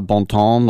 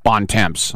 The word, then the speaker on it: Bontemps: One time I interviewed Tim Bontemps and I said, do you say bon ton? Like good, it means good times in French. Do you say bon ton? And he said, it's pronounced Bontemps.